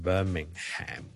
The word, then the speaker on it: Birmingham: 如果你美國人咧，可能會讀埋個 Ham 出嚟，Birmingham 咧就可能會讀成 Birmingham 咁樣。